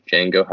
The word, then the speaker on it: Django: My name's Django. How do you spell that? D. D is silent.